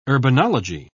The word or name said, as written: urbanology